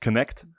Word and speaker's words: Knecht: Knecht? Wie spricht man den aus? – Connect.